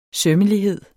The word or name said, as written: sømmelighed